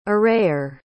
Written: arrayer

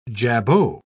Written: jabot

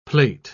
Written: plait

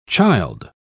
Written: childe

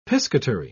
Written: piscatory

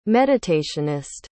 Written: meditationist

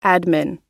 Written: admin

